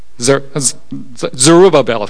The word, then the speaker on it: Zerubbabel: How do you say that? Zerubbabel, thank you.